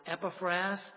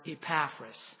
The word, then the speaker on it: Epaphras: I have heard his name pronounced two ways by Bible teachers. I've heard it pronounced Epaphras, and I've heard it pronounced Epaphras.